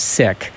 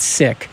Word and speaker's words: Sikh: I mean, the proper pronunciation is Sikh, but I really enjoy Sikh over Sikh. So I don't know if that's true. – Yeah, I've asked around. It's Sikh.